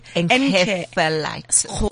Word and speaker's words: encephalitis: Some parents have reported mood swings, joint and muscle pain, autism, lupus, multiple sclerosis, encephalitis. Am I pronouncing it correctly? – Encephalitis. – Encephalitis.